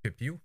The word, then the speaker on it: Pepiot: Pepiot. Sorry, Ryan. – Pepiot.